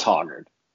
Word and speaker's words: Hoggard: It's Hoggard. The Hog Dog. Yeah, the same way it's Penix, it's Hoggard.